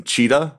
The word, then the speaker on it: Cheetah: My name is Jason Cheetah, like the animal, not Toshita.